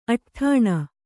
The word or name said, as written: ಅಡ್ಡನೆ